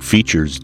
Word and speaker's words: Fischer's: Maybe the geriatric form should be referred to as something like Fischer's disease. Yes. Is that how you pronounce the gentleman's name? – Yes, Oscar Fischer.